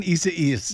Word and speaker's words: Isaias: Hurricane, how do I say this, Desi Duane? Isaias. I see what you're doing. You're laughing at me. Hurricane Isaias. – There you go.